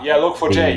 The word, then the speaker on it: Log4j: And also, I poked around your website earlier, and I saw you have how do you pronounce this? Is it LogForge or Log4j? – Yeah, Log4j, the tracker.